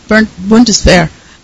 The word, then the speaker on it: Bundeswehr: Seidler, who is a historian for the Bundeswehr, or something like, how do you pronounce it, in Germany, Bundeswehr.